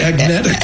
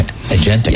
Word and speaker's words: agentic: You're very agnetic. – Yeah, exactly. I'm getting there. Agentic.